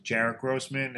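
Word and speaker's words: Jaret Grossman: J-A-R-E-T and G-R-O-S-S-M-A-N. You could also follow me on YouTube, Instagram, Facebook, Jarrett Grossman.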